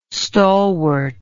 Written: stalwart

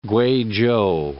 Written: Guizhou

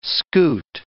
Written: scute

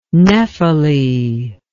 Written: nephele

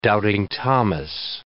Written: doubting Thomas

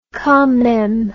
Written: quand meme

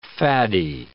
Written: faddy

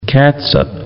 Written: catsup

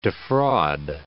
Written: defraud

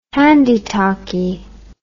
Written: handie talkie

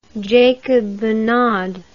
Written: Jacob Monod

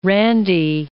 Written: randy

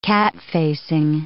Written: catfacing

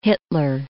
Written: Hitler

